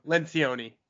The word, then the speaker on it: Lencioni: Is it Lencioni? Is it a hard C? – Lencioni. – Lencioni. I was right the first time, kind of.